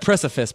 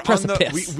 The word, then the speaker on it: precipice: I said it wrong. I said precipice by accident. – We it's precipice.